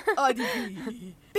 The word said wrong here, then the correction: آدیتیا: ببینم درست تلفظش کردم. – آدیتیا، خیلی راحت.